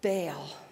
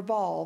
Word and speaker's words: Baal: But then there was also God Baal or Baal. Both pronunciations are correct.